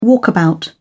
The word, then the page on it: walkabout: British English